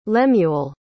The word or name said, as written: lemuel